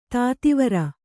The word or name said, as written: ಧಾತುವೈರಿ